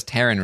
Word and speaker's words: Taryn: And I was just like, man, I really sympathize with this because nobody ever says Taryn Red. Just like these cruel mothers giving us weird names that are hard to pronounce. – Why? What do people say? – I think one of the things that when I was a kid, people used to call me Terrence occasionally.